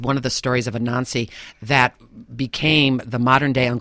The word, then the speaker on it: Anansi: Anansi, I believe I'm pronouncing Anansi it.